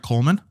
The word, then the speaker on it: Kohlman: Kohlman? – It is.